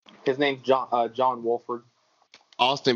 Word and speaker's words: Wolford: Is it John Walford? – Oh yeah, John bro. John Wolford is um so he played at he played at Wake, Wake Forest.